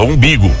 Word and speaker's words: umbigo: E pessoa com imbigo sartado. Não é imbigo, é umbigo. E como é que eu falei? Imbigo. Como é que fala? Umbigo.